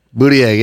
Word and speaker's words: Buttigieg: Buttigieg. – Buttigieg, yeah.